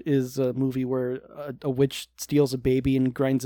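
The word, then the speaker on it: The VVitch: It's witch, but it's spelled with two V's.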